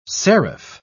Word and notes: serif: British English

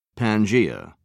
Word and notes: pangaea: British English

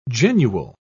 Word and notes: genual: British English

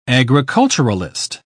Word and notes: agriculturalist: British English

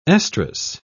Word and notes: estrous: British English

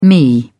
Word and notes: me: British English

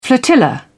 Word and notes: flotilla: British English

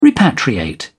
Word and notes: repatriate: British English

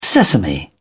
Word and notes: sesame: British English

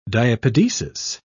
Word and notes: diapedesis: British English

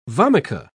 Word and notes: vomica: British English

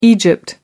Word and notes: egypt: American English

